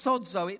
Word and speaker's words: sozo: It's called, it's pronounced sozo.